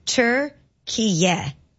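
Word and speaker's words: Türkiye: The official pronunciation of the country's recognized name is Turkiye. – We're not playing Turkey then. – You weren't that far off. – T-U-R.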